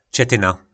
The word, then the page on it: cetyna: po našimu